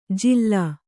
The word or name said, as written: ಜಿಲ್ಲಾ